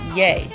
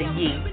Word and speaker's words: Ye: The rapper, he released his latest album on Thursday in Wyoming called Ye, or rather Ye, or however way you want to pronounce it.